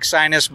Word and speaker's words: Sinus: The first one we're standing right next to, I believe it's pronounced Sinus. – That's correct. S-I-N-U-S. It sounds, or it looks like sinus, but it's really a Sinus.